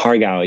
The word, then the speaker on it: har gow: The crystal shrimp dumplings, har gow, is that how you pronounce it? – Har gow, yeah.